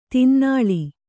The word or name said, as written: ತಿನ್ನಾಳಿ